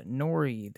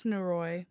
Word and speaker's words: Noroi: After that, we have the movie review of Nori, the Curse. Did I say that right? – I think it's Noroi, but I'm not 100% positive.